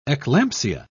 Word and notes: eclampsia: British English

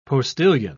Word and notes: postillion: British English

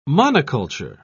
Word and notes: monoculture: British English

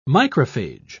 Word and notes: microphage: British English

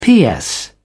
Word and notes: ps: British English

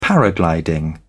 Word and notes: paragliding: British English

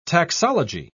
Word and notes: taxology: British English

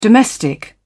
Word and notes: domestic: British English

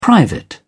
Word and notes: private: British English

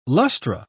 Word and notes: lustra: British English

